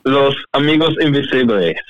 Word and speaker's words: Los Amigos Invisibles: It's called Los Amigos Invisible. – Yes, Los Amigos Invisibles.